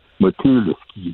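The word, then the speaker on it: Motulsky: Moi, je le prononce à la française, Motowski, mais euh, Motulski.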